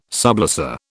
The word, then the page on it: sublessor: British English